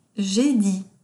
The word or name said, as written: J'ai dit